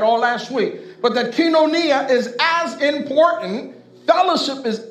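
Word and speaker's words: kinonia (κοινωνία): It sounds, phonetically, it sounds like koinonia, right? Koinonia, I'm sorry, but it's not koinonia. It's pronounced kinonia.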